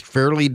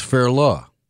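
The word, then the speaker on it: Fairleigh: Well, no, I'm wrong, and you're wrong, texter. According to Forvo, it's Fairla Dickinson. F-A-I-R hyphen L-U-H, Dickinson. We can agree on Dickinson.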